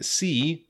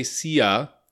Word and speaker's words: sea: Ocean is O-C-E-A-N, ocean, and sea is S-E-A. So be careful again, we don't say sia, we just say sea. The A is silent.